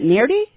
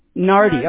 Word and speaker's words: Nardy: N-A-R-D-Y. – Mm-hmm. D-Y. How do you pronounce that? I want to pronounce it properly. Is it Nardy? – Nardy. – Nardy, okay.